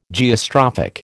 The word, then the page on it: Geostrophic: American English